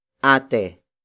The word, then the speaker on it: ate: Ate, sha, ni, jen. Ate, sha, ni, jen. The first word is ate. Ate. Ate. Ate is spelled as A-T-E.